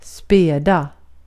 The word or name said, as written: späda